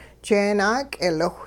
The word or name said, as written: jeenaak'eeliwh